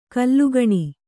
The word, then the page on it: ಘಲಕನೆ: kannada